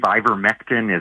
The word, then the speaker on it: ivermectin: A text uh, wondering about ivermectin. Is that how I say it correctly? I believe ivermectin is how it's pronounced.